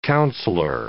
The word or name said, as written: councilor